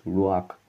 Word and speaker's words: Ruach: The Hebrew word simply pronounced there as Ruach. Ruach.